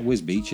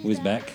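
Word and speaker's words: Wisbech: It's not Wisbeach, is it? Wisbeck.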